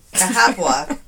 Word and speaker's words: Cahaba: This one is um, I'm going to try to pronounce it Cahaba, Alabama. It doesn't sound like it doesn't sound right, but that's the way it's pronounced.